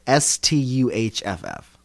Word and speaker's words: Stuhff: pronounced, it's spelled S T U H F F. Stuhff.